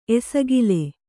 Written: ಎಸಗಲ

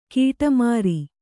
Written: ಗಡಿಮೀರು

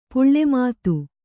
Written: ಪುಳ್ಳೆಮಾತು

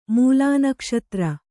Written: ಮೂಲನಕ್ಷತ್ರ

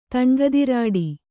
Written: ತಣ್ಗದಿರಾಡಿ

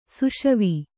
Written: ಸುಷವಿ

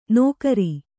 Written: ನೋಕರಿ

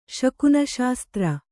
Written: ಶಕುನಶಾಸ್ತ್ರ

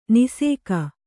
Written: ನಾಶಕ

